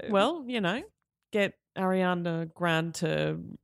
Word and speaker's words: Grande: Pronounce the E. – Grande.